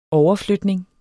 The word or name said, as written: overflytning